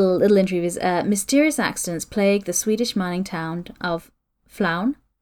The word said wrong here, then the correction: Falun: I don't know if I said that right. F-A-L-U-N. – Oh, okay. – Falun? Falun? Falun. – Falun. F-F-A... – L-U-N. – Falun. Yeah, yeah, sure.